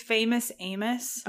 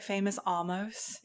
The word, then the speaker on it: Famous Amos: The cookie's Famous Amos. – Oh, yes, you did. – Yeah, I was calling it Famous Almost.